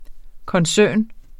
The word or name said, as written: koncern